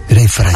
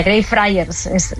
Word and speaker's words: Greyfriars: Greyfriars. – Así se pronuncia, Greyfriars.